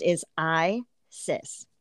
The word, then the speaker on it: Isis: Her name uh, pronounced is I Sis, also known as, I'm probably going to say this wrong, Ah Set.